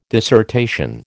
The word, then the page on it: dissertation: American English